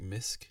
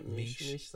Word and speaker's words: Misch: Tom Misch, or like Misk. – It sounds right. Misch sounds right.